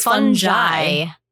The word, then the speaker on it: fungi: Or but it's fungi, as we learned. It's a soft G.